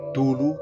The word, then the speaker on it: Cthulhu: El origen, en la perdida ciudad submarina de Riley, del monstruoso semidios Cthulhu, que algunos pronuncian Tulu, Cthulhu, en fin.